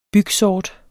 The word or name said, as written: bygsort